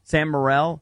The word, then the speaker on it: Morrell: Is that how you say his name? – Sam Morrell. Yeah.